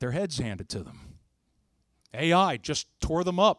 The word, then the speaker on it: Ai: Because right after they got done with Jericho, there was a little tiny town, like Mechanicsburg size, of Ai. How do you spell that? A-I. That's it. So if you're writing, make this real easy on you. A-I. Little town called Ai.